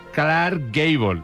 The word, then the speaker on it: Clark Gable: Con Carl, el inolvidable Clark Gable.